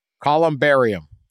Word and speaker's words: columbarium: Last week when I talked about the spreading the ashes, or Nebraska had that plan to I know how to say it now. – Oh yeah, we got lectured on your pronunciation. – Columbarium. That's what they're called. It's columbarium. I got it all wrong.